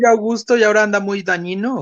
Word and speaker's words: Dagnino: Oigan todos, pero más tú, Valeria Torices, ya pusieron nuevo director del SAT o jefe del SAT, Antonio Martínez Dañino o Dagnino. Uh-huh. Es el nuevo. ¿Dagnino? Es que, güey, GN se pronuncia como Ñe, como ñ, güey.